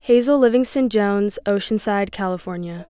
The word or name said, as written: Hazel Livingston-Jones